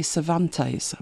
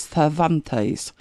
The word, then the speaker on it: Cervantes: In English, you would say Cervantes, but I believe it's Cervantes.